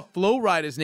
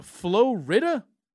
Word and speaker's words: Flo Rida: When I first saw Flo Rida's name written, I was like, is that guy's name Flo Rida? Because it's spelled like Florida. Mm. But it's two words.